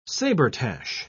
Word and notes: sabretache: British English

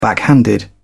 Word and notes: backhanded: British English

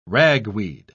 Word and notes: ragweed: British English

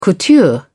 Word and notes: couture: British English

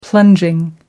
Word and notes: plunging: American English

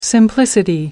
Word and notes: simplicity: American English

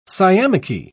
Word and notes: sciamachy: British English

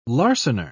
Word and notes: larcener: British English

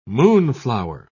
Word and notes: moonflower: British English

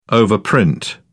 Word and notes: overprint: British English

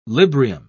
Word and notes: librium: British English

